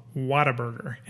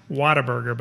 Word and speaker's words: Whataburger: and in Texas it's pronounced Whataburger, but it looks Whataburger. – Like Whataburger. But it's pronounced. – And then you always get. I remember having adults correct me when I was little and being like, "Oh, honey, you mean what a burger?"